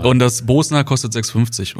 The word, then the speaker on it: Bosna: Ja, und die Bratwurst, beziehungsweise das Bosna, wie mir gesagt wurde. Nicht Bosna, ne? – Bosna. Das hätte ich jetzt gefragt. Wirklich Bosna? – Bosna, ja. Also ich habe es ja im Video tausendmal falsch ausgesprochen.